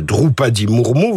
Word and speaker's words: Droupadi Murmu: En se proclamant présidente du Barat, devant les convives étrangers du G20, Madame euh, Droupadi Murmu,